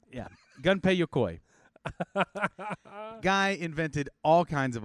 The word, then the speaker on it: Gunpei Yokoi: His name is Gunpei Yokoi. – Oh, I was close. – Yeah, I say his name is Gunpei Yokoi.